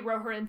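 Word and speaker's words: Rohirrim: Thank you, Roharen, for your three-syllable name.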